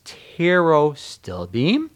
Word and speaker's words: pterostilbene: We're looking at resveratrol, pterostilbene, which you see the PTRO, so it's pterostilbene.